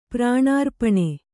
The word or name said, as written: ಪ್ರಾಣಾರ್ಪಣೆ